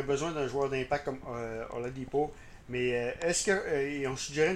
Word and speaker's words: Oladipo: Hier, j'écoutais l'émission Alley-Hoop 360 au 91.9 et on posait la question, est-ce que Victor Oladipo... j'ai de la misère à le prononcer. Tu vois le mot, Oladipo.